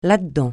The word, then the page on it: là-dedans: francais